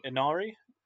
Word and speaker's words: Inari: Um, yeah, we we then roll into chapter twelve, and we return back to the porn set where Harry meets um, Inari. Am I pronouncing that correctly? Yeah, that's how James Marsters pronounced it, and therefore, yeah, it's the way that it should be pronounced.